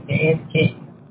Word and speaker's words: Dan King: Dan, D-A-N, last name, King, K-I-N-G, Dan King.